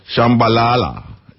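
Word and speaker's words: Shabalala: Yeah, we're paying tribute to a great group, Ladysmith Black Mambaza, the leader, Joseph Shambabala. We want to pronounce the name right, and every time we get that, we can't pronounce the name right. Shambalala.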